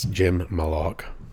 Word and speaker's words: Jim Malaugh: One is I just pronounced your name incorrectly, I believe. – Everybody does. – Say it correctly. – It's Jim Malaugh.